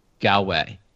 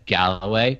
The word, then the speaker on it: Galway: And I probably said Galloway because I think it's like I think that's a golfing brand here. I don't know. It's a word I know how to say. So I probably just said a word I know how to say rather than the actual word.